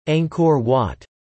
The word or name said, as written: Angkor Wat